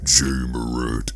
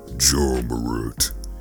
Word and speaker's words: Ja Morant: And before I end this podcast episode, yes, I have been saying his name wrong. It is not J. Morant, it is Ja Morant.